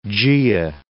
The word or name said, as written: Gaea